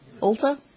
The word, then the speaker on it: Ulta: You get a free blowout and style at Ulta. Is that how you pronounce it? Ulta? – Yeah, it's Ulta.